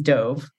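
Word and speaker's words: Dov: But it turns out it actually is Dove, confirmed by my audiobook producer. So okay, good. I mean, it's your character. But anyway, yes, Dove.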